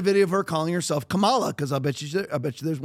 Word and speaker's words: Kamala: So he says, Don Lemon says that her name is to be pronounced Kamala, like comma.